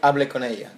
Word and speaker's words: Hable con ella: And then the one that we're going to talk about is a Spanish film by Pedro Almodóvar called Talk to Her, or Hable con Ella. Can you say it properly? – Hable con Ella.